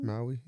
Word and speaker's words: Maui: Maui, yeah.